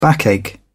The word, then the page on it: backache: British English